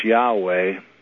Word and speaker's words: Yahweh: So when we see Lord, we should recognize that we're seeing the name of God, and it's Yahweh, and that's the best pronunciation that I know.